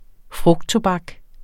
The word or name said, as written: frugttobak